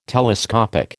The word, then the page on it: telescopic: American English